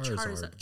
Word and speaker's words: Charizard: Wait, what's the correct pronunciation? Charizard or Charizard? Charizard. Like Charmeleon.